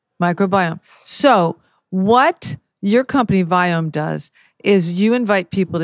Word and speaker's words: Viome: So it's we as in Victor, I-O-M-E. So in case you're wondering when I say Viome, I really mean, you know, whatever that is.